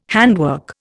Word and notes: handwork: Indian

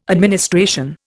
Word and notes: administration: Indian

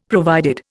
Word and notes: provided: Indian

